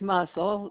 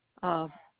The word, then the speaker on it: Muscle: It's muscle, like a muscle in your arm. Muscle.